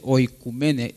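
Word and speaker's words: oikoumene: Aquí dice oikumene, O-U, pero O-U junto se pronuncia U, entonces es oikumene.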